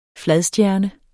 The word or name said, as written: fladstjerne